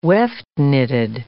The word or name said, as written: weft-knitted